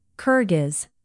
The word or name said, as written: kyrgyz